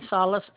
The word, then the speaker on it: Salas: Lorraine Salas, L-O-R-R-A-I-N-E, Salas, S-A-L-A-S. – Can you spell your last name for me again? – S-A-L-A-S.